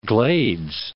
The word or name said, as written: Glades